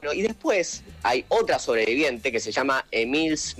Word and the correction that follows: Emilce: Moler, ¿Cómo se dice? Eh, Emils, Bueno. – Emilce.